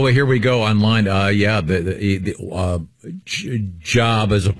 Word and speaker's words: Job: Now, because that is pronounced Job as opposed to Job.